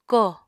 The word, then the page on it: kóò: Apatani